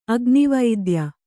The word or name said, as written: ಅಗ್ನಿವೈದ್ಯ